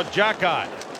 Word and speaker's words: Xhekaj: Now Jackie. Spelled X-H-E-K-A-J. So it sounds exactly the way it's spelled.